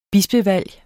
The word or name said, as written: bispevalg